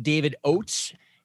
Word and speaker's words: David Oates: We are here today with uh, our guest, David Oates.